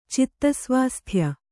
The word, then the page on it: ಚಿತ್ತಸ್ವಾಸ್ಥ್ಯ: citta svāsthya